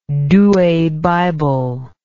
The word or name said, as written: Douay Bible